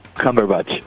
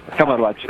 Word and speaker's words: Cumberbatch: Pero bueno, tenemos también a Benedict Cumberbatch en The Imitation Game. – A ver, para. ¿cómo es? – Por favor. – Cumberbatch. – Cumberbatch.